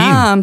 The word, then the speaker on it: Resurgam: Ich weiß nicht, Resurgam, wie würdest du es aussprechen? – Resurgam, wahrscheinlich ist doch Latein. – Resurgam, stimmt, das ist Latein.